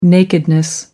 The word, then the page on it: nakedness: American English